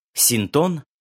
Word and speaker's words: Синтон: Синтон, напоминаю ударение на втором слоге, из опыта которого рождаются книги мои и моих коллег, давно уже не клуб.